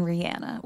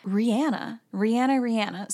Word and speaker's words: Rihanna: I wanted to see what was going on with the friendship between Cara Delevingne and Rihanna, which, by the way, is how you pronounce her name. I've been hearing Rihanna like my entire life, and until I looked it up, because, you know, me and pronouncing things correctly, it's like oil and water over here. Rihanna.